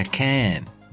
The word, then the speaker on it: Cannes: De hecho, se pronuncia Cannes.